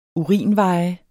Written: urinveje

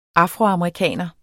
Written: AFROAMERIKANER

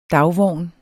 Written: dagvogn

